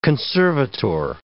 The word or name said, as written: conservator